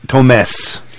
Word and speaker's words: Tomes: Tomes. I pronounce it the Spanish way.